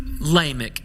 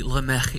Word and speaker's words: Lamech: Lamech, as I was told in East Texas growing up, but maybe Lamech is a better pronunciation.